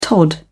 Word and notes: tod: British English

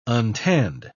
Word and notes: untanned: British English

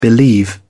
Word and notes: believe: British English

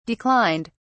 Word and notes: declined: British English

